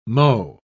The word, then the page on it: mho: British English